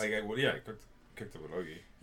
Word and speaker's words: bulgogi: We ate some bulgogi. Is that how you say it? Bulgogi? – Yeah.